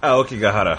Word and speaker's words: Aokigahara: That uh, uh, Aokigahara.